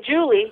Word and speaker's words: Jully: And, you know, for those of you listening, my parents, I don't know what they were smoking when they named me, but my name is spelled Jully. So now when I say Julie, you'll know the spelling.